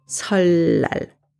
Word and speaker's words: 설날: 설날, New Year's Day, is pronounced 설날.